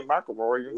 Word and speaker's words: McIlroy: You sound like you got your mouth full of M&M's, brother. Rory McIlroy. – Rory. – I got you. – Yo, he's Irish. I don't know how to say it, bro. McIlroy. – McIlroy. There you go.